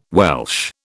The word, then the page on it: Welch: British English